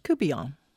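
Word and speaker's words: courtbouillon: Looks like court bouillon, but it's pronounced cubillon.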